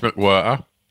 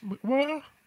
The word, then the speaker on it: McWhirter: Anyone have a little look at Norris McWhirter? – Surname rhyme with Squirter.